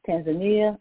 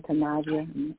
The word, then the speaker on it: Tanzania: and also Tanzania or Tanzania, depending on the uh, various pronounced.